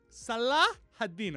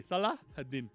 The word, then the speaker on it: Salah ad-Din: Salahadín, así se pronuncia, ¿eh? Tenés un tema tan... con el, con el árabe, me parece. He, detectado una debilidad tuya. He detectado un soft spot para mi pronunciación árabe. Se dice así: Salah ad-Din.